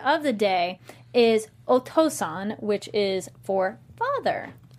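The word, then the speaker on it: Otōsan: But if you've heard it on anime, we did Okasan for mom for Mother's Day, so Otosan for father. Yay! – Otosan, I gotta call you.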